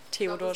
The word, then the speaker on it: Theodor: zur Aussprache, Theodor oder Theodor? – So wie Theodor Storm.